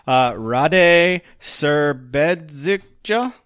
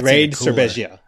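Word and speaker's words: Rade Šerbedžija: Did I pronounce that anywhere close? – Yeah, I've heard him called Rade Serbedzicja. So I don't know. – Raid. Oh, that's Raid Serbezia. Yeah, that's what I think. That's how I think you say his name.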